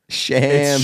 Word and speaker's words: Shams: I'll see people tweet Shams, S-H-A-M-S, and I'll be like, that's not how it's pronounced. I can just like read as they write the word. I'm like, I know you mispronounced that word. You motherfucker. – Shams. – It's Shams.